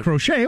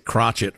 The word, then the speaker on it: crochet: Knit uh, or crochet? – Well, that'd be kind of cool to learn how to do, I guess. – We pronounce it crotchet around my house just to be amusing.